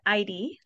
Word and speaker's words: Eide: So Jeanette Eide, last name is spelled E-I-D-E.